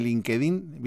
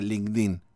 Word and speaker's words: LinkedIn: Pongan en LinkedIn, viste que no se dice LinkedIn, es LinkedIn.